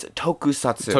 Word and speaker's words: tokusatsu: But, like, I mean, one of the things that, like, makes, like, was it, like, pronounced how do you say it? Like, tokatsu? I don't want to, like, tonkatsu. Yeah. Oh my god, I almost said tonkatsu is a form of meat. Sorry, it's tokusatsu.